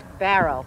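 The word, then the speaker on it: Bowral: My guidebook says it's spelled Barrel, but it's pronounced Barrel.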